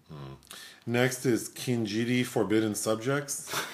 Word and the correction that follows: Kinjite: Is that how you say it? – No. I think it's Kinjite.